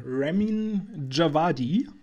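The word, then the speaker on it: Ramin Djawadi: Ähm, interessant ist noch äh, die Musik von äh, Ramin Javadi. Ich hoffe, er ist richtig ausgesprochen. Hätte nicht schöner aussprechen können.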